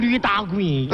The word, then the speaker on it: 驴打滚: 什么驴打滚？ – 你别这么发音了，我觉得那个吃的那个东西都不粘了。 – 它叫驴打滚。它可不是一头活驴在那打滚。